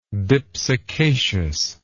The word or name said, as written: dipsacaceous